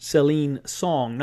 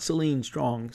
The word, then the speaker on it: Celine Song: Yes, Past Lives made my number 5, and sorry, I mispronounced Celine Song, not Celine Strong.